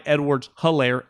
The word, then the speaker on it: Edwards-Helaire: And we're like, damn, they got this Clyde Edwards-Helaire, H is silent.